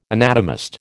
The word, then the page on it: anatomist: American English